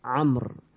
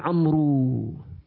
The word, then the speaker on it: Amr: When you see that waw, you'll pronunciate it Amr. Without waw, Umar. Unfortunately, some Arab nowadays, they don't know this. They call their children Amru. There's no Amru in Arabic.